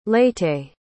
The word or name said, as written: leyte